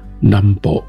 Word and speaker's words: 南方: 由台湾的机构跟人员所提供的宝贵技术和经验，关键形塑了日本战前在华南的非正式帝国，以及对所谓 Nambo，那这是日语的发音，南方指的是华南、东南亚还有南太平洋这块区域进行军事占领。